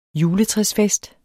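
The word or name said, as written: juletræsfest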